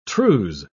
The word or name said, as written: trews